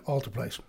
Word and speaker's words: Alteplase: Right off the bat, did I pronounce that drug properly? – Yeah, Alteplase.